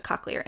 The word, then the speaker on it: cochlear: And eventually gets like a cochlear implant or... God, I'm probably mispronouncing that. No, cochlear is right.